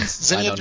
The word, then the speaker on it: Zinnia: Zania Jones? Yeah. – Z-I-N-N-I-A Jones.